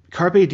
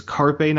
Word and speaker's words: Carpe: Copper, this was a really strong read, but Carpe Diem being such a central theme for this monologue, you have to pronounce it correctly. It's Carpe, not Carp.